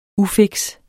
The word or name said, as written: UFIKST